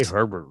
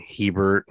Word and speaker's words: Herbert: How can you say Herbert wrong? – I keep saying Hebert and other shit.